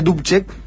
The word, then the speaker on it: Dubček: Pero finalmente el régimen militar, que también todavía sobrevive en Checoslovaquia, captura a Dubček, en realidad se escribe Dubček, que tiene como una, sí, como un tildecito, así, creo que se dice Dubček.